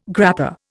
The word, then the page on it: grappa: Indian